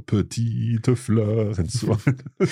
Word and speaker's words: petite: Petite. Now, I'm sure I've heard petite. – Again, some regions might overpronounce, in my opinion, okay. – pronounce a final E, but other than that, it's just the T that's pronounced here. Yeah. So petit, petite.